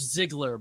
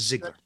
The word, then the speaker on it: Ziegler: It's Ziegler, but it's Ziegler. Such an ethnic last name, German, that I give people a lot of rope.